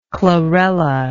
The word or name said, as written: chlorellaceous